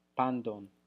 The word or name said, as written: pandon